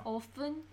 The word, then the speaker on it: often: 그 티 소리 안 나와요. – Often? – And a question, yes.